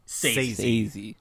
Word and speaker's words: Scorsese: Is it Sazy or is it Sezzy? – Martin Scorsese. – Sazy. – Like, thank you. – Say and then the letter Z.